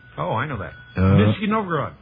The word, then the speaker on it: Nizhny Novgorod: Nizhny Novgorod.